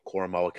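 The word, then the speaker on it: Koramoah: And I'm going to butcher this name from Notre Dame, Jeremiah Owusu-Koramoke. – Koramoa.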